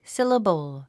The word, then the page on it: syllable: síləbl; スィラボォ